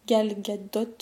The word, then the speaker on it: Gal Gadot: Et quand Gal Gadot, je crois que ça se prononce comme ça, Gal Gadot.